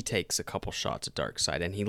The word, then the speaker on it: Darkseid: Anyway, this minute of the movie, yeah, Darkseid is a very formidable. – Yeah, you're saying it correctly. You said it correctly.